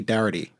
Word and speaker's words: Darity: But also, Glenn, something that I saw you talk to, I believe it's Sandy Darity, if I'm pronouncing his last name correctly.